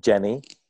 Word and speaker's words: dgeni: And so I built this funky little tool called Jenny, which is spelled D-G-E-N-I.